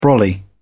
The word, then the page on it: brolly: British English